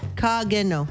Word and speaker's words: Kakino: Kageno. – Kageno. – And it's spelled K-A-K-I-N-O, so that second K kind of sounds like a G. – Yes. – Kageno. – Kageno. – Okay. – K-A-K-I-N-O.